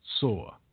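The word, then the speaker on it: Soar (צור): That was the ancient city called, seems to be spelled T-Y-R-E. Some would call it Tyre. Sometimes it's called Tyrus. But it's pronounced in the Hebrew like Soar.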